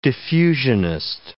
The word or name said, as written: diffusionist